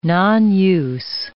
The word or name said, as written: nonuse